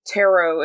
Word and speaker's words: tarot: And tarot is spelled, of course, T-A-R-O-T. And so the T is silent, but that's because of French and their interpretation of how they said tarot.